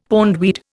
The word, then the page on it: pondweed: Indian